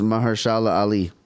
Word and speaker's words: Mahershala Ali: It's hard to pronounce it, but it's Mahershala Ali.